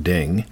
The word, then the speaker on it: Ting: So the word ding, spelled T-I-N-G, pronounced ding, the 50th hexagram, my question was, as I said, what will the effects of the solar eclipse on the 8th be?